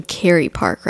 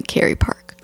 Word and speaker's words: Kerry Park: You said Kiri Park earlier today. And I was like, you mean Kerry Park, right? It's definitely Kerry Park. – Okay. I don't know why I always. – It's spelled like Kerry, like the name Kerry.